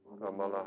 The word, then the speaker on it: Amala: Amala. – And the meaning. – A M A L A.